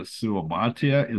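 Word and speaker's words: Suamataia: The kid from BYU, Kingsley Suamata, is that how it's pronounced? – I believe so. I think yeah. – You nailed it.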